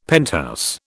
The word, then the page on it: penthouse: British English